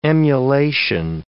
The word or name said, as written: emulation